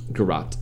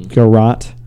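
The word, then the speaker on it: garrote: then garroting her with a nylon stocking. Did I say that right? – Garrot. – Mm-hmm. Garrot.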